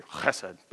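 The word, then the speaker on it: chesed (חסד): That word steadfast love in Hebrew is the word chesed. Really the proper way to say it, you got to clear chesed, but who's got time for that?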